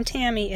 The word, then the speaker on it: Tammy: Ursus is Latin for bear and is spelled U-R-S-U-S. And my first name, Tammy, is T-A-M-M-Y.